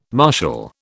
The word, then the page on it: martial: British English